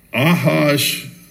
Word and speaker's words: Ahasuerus: But I think the correct pronunciation is Ahasuerus. Looks like Ahasuerus to me, but it's not the way you pronounce it.